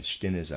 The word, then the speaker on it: Stinnes: Readers aside, Stinnes, I'm pronouncing it that way, is spelled S-T-I-N-N-E-S, Stinnes.